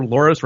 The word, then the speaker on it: Loras: Remember, Loras rhymes with Floris.